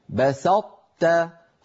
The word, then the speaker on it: بسطت: لئن بسطت إلي يدك، بسطت فأطبقت لساني على طاء غير مقلقلة وجافيته عن تاء مرققة.